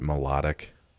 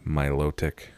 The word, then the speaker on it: Milotic: When I was a kid, I called it Melodic. – I think I did too. My brother does still tell me. Milotic.